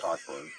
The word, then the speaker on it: Cockburn: It's fucking pronounced Cockburn. Like, I understand, like, how, when, where, anywhere is a C and a K silent? – Like, I have, like, video of it.